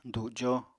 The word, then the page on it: dujo: apatani